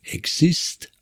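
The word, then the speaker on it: XIST: Das Akronym steht für X Inactive Specific Transcripts und wird als EXIST ausgesprochen.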